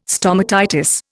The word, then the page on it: stomatitis: Indian